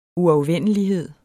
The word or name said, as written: uafvendelighed